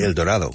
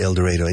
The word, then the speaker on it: El Dorado: El Dorado, which is El Dorado. Mm-hmm. They say El Dorado. – And, really? I thought Yellville. They were saying El Dorado. – No, it's they pronounce it El Dorado.